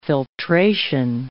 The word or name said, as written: filtration